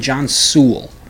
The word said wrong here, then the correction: Searle: It's John Searle. S-E-R-L, Searle.